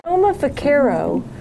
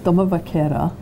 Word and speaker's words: Doma Vaquera: The Doma Vaquero, when you were riding that, explain Doma Vaquero. – Okay, it's Doma Vaquera.